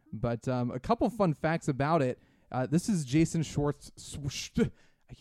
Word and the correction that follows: Jason Schwartzman: Jason Schwartzman's first movie ever.